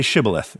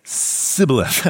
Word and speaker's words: Shibboleth: And if he answered no, they said, "Well, please say Shibboleth then." And if he said Sibboleth, I don't know, because he could not pronounce it correctly, they seized him and executed him at the fords of the Jordan.